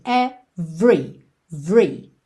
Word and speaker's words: every: 99,9% das vezes que a gente vê a palavra every, esse E do meio é mudo, ou seja, do V a gente vai direto para o R. Every, every, ok?